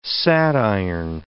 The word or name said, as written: sadiron